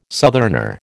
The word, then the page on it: southerner: American English